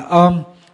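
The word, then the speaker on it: om: Thì cái chữ án này đó, chữ Phạn đọc là ôm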